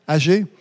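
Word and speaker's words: Aggée: Alors, un autre ouvrier, homme de Dieu, prophète, Agé, c'est bien prononcé en français, Agé.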